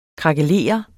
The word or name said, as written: krakelere